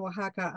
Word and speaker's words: Oaxaca: Your pronunciation of Sonora and Oaxaca are perfect, by the way.